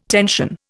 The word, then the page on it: tension: Indian